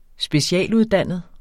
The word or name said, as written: specialuddannet